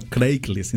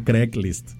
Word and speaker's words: Craigslist: Es que fíjate, en mexicano se dice Craigslist. – Craigslist. – No Craigslist, se dice Craigslist. – Craig. – Como si fuera Greg, pero con C.